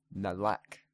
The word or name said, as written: Melek